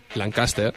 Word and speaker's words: Lancaster: Por favor, pronúncialo bien. – Lancaster. – Lancaster, pues ya está.